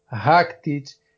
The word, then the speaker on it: Rakitic: É Modric, Rakitic. A sílaba tônica é sempre a primeira desses croatas aí.